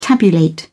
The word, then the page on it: tabulate: British English